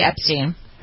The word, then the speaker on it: Epstein: Is it Steen or Stein? I don't know how to pronounce that. That's the Epstein. – Epstein, okay.